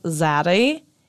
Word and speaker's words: Zaddy: It's pronounced Zaddy. It's spelled Z-A-D-D-Y, and it's like mm-hmm. a sexier, more sultry way of saying daddy.